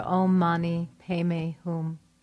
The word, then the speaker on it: Om Mani Padme Hum: In Pali, in Tibetan tradition, they pronounce this mantra, Om Mani Padme Hum.